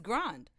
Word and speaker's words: Grande: I mean, she plays into the Grande, and that's not how you pronounce her last name. – What is it? – It's Grande.